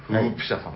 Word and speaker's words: 輪骨: 輪骨と書いて、フープ。はい。 – はいはいはいはい。 – フープ社さんだその説は、申し訳ありません。